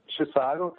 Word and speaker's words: Chisago: So it's Chisago County.